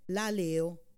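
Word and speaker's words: laleo (λαλέω): Il secondo termine, quando dice chiunque epo e crederà che quanto dice, questa seconda parola, in greco è la parola laleo o laleo, con una L, laleo, come si pronuncia.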